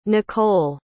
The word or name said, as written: nichole